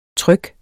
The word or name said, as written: tryg